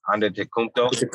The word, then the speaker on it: Antetokounmpo: I still can't fucking say his name. Antetokounmpo. Yeah, there you go. I got it.